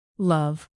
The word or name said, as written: love